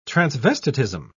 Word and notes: transvestitism: British English

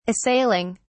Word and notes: assailing: British English

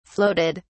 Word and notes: floated: British English

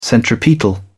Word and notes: centripetal: British English

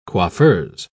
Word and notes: coiffeuse: British English

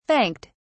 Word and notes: thanked: British English

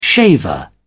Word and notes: shaver: British English

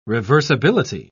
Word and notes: reversibility: British English

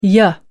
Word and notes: ya: British English

